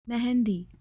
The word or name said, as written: Mehendi